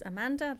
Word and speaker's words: Amanda: Yeah, my name is Amanda, that's A-M-A-N-D-A. Thompson, which is spelt T-H-O-M-P-S-O-N.